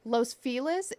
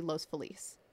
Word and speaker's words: Los Feliz: But before we continue, I do want to say to any of our listeners that are not from Los Angeles, the neighborhood of Los Feliz is often referred to as Los Feliz. And that's just because we use our American accents when pronouncing Spanish words in California. – Wait, so which way does everyone say it? – Los Feliz is the way that we pronounce it in LA. The proper way to pronounce it would be Los Feliz.